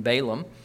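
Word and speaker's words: Bilam (בלעם): His Hebrew name is Bilam rather than Balaam.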